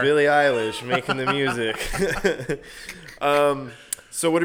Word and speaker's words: Eilish: Is it Billie Eilish or Eyelash? – It's Eilish, and it makes you sound very 40 years old when you say Eyelash.